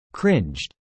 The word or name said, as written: cringed